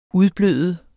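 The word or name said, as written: UDBLØDEDE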